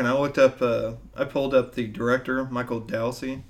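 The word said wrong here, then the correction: Dowse: I think I pronounced that right. No, Douse.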